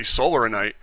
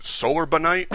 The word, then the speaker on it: solarinite: And I think it's supposed to be solarinite, because that's what one of the other people calls it, and every time I look it up anywhere, that's how it's spelled.